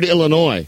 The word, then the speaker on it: Illinois: Teach them how to pronounce the word Illinois. – Yeah. – How to spell it. The S is silent.